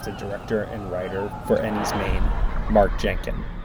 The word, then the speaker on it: Enys Men: Yeah, it's Maine rather than men. It's spelled men, pronounced Maine, because it's Cornish word for stone.